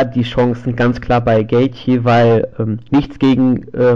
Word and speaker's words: Gaethje: Melvin Giller tritt um die in, im Lightweight bout um die Lightweight Championship gegen den Titelträger Justin Gaethje an, so will ich ihn mal aussprechen. Kann sogar richtig. Bitte? Ist sogar richtig so.